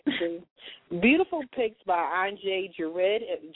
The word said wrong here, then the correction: Gerard: Am I saying his name right? Because I don't want to chop it up. – Gerard.